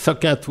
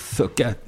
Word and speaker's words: Sochat: Maurice Socat, oui, ça s'écrit Sochat. Mais ça se prononce Socat.